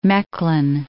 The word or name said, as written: Mechlin